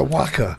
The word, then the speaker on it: Joaquin: Because although it's spelled a bit like Joaquin, you must be Joaquin Phoenix. – It's lovely stuff. – Lovely. And now he's the Waka.